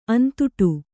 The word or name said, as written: ಅಂತುಟು